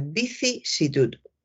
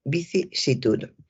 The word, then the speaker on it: vicisitud: Y hay que tener cuidado porque hay personas que efectivamente se equivocan y dicen vicisitud, dicen vicisitud, que también lo he oído, vicisitud, eh, o dicen visic, visic es una palabra que cuesta. Eh, la unión en estas dos sílabas, sici, vicisitud, eh, son, son difíciles cuando se habla de una manera coloquial y, y deprisa. Así que hay que recordar vicisitud, vicisitud. Continúo con un periodista que estaba hablando de, un político.